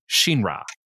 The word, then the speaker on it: Shinra: And so whenever his name is pronounced, the director specifically wants a flipped R on Ra as opposed to Shinra.